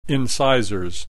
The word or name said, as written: incisors